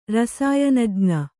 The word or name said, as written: ರಸಾಯನಜ್ಞೆ